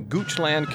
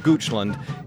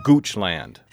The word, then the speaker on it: Goochland: We recently did a show on a controversy in the Goochland County Public Schools, and Sherry Heiter of Bristol, Virginia, corrected my pronunciation. It's not Goochland, it's Goochland. That's Goochland. Thanks, Sherry.